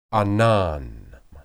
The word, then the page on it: anan: Hebrew